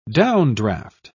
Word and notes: downdraft: British English

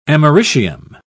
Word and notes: americium: British English

